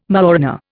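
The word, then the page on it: mañana: Indian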